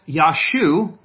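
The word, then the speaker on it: Yeshua: So in fact, this Yashu, which is the Hebrew way of pronouncing the name, is Yah's salvation.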